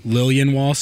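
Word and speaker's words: Liljenwall: Lilienwall, so the J is just a Y.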